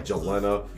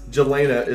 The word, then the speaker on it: Jelena: I mean, we were like Jelena, like just super flat, like Squidward on SpongeBob, just really bad. But yes, Jelena is the correct pronunciation, so thank you for that.